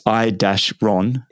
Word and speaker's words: Iron: And then so moving forward now, you know, Iron, is it pronounced Iron? Because it's I-Ron. – Yeah, it's Iron. – Like Iron, sports management agency, which I love that because it's obviously got the Iron Man, but it's also got Ron for Ronnie.